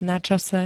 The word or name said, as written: načase